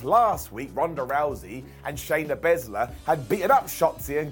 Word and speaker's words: Shayna Baszler: Shotzi versus Shayna Baszler.